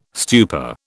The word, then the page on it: stupor: British English